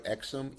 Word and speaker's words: Exum: So let's start with my Jabari Exum, E-X-U-M is my actual name, Jabari Exum. So that's the pronunciation.